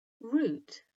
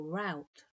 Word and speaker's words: root: Root rhymes with hoot in British English, but could rhyme with hoot or shout in American English, so root or rout for Americans.